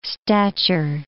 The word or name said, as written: stature